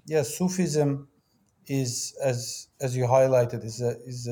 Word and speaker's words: Sufism: Sufism.